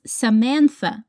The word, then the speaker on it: Samantha: Her name is Samantha. Repeat Samantha. Put your tongue between your teeth.